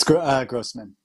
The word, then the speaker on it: Grosman: It's Grosman.